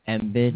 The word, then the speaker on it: Embiid: Joel Embiid.